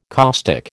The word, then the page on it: caustic: American English